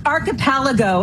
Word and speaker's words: Archipelago: Archipelago, and it took me a long time, too.